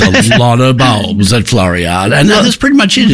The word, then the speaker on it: Floriade: Floriade.